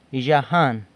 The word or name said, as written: Yzhajan